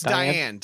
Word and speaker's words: Diahann: Is that how you say her name? Diahann. – Oh, that's Diane. Diane Carroll.